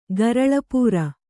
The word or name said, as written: ಗರಲಪೂರ